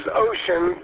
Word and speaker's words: Wouxun: That's kind of conflicting because it is Chinese, but it's pronounced as ocean, but it's spelled as W-O-U-X-U-N, and the model number is the DWC30WIN.